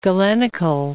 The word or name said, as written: Galenical